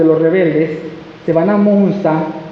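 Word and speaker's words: Münster: ¿Pero así se llama? ¿Qué se dice así, Carla? Munsta. Sí, porque tú sabes alemán. Soy en alemán, Munsta.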